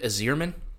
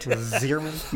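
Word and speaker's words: Yzerman: I'll correct you, Joe. It's Steve Azierman, actually. – Right. – Azierman.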